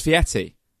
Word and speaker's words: Fieri: No, Fieri. Guy Fieri. – It's pronounced Fieri.